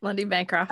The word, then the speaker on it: Lundy Bancroft: That's Lundy, how do you say it? Lundy Bancroft? – Yep, Lundy Bancroft.